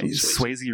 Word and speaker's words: Swayze: Yeah, Swayze rhymes with hazy.